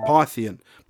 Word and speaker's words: Pythian: It is said that by the end of the war, over 2,000 soldiers had been admitted into Python Castle. Pythian. Pythian Castle!